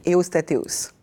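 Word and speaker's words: Ewostatewos: Takla, Emano et Evostateios. – Tekla Aymanot et Eostateos.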